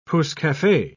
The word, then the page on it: pousse-cafe: British English